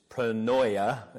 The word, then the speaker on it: pronoia: It's pronoia is the way I'm going to say it.